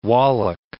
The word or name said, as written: Wallach